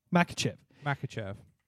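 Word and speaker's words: Makhachev: Makachev.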